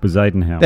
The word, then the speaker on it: Bezuidenhout: Udenhout. How do you say that name? – Bezuidenhout.